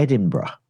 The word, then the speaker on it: Edinburgh: Edinburgh. – Ah. – Not Edinburgh, Edinburgh.